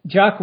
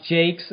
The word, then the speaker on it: Jacques: Yes, Vienna Jacques, or it's also could be pronounced Jakes.